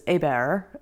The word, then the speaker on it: Hébert: My birth name was Hébert. It's spelled H-E-B-E-R-T. So it's very Cajun French.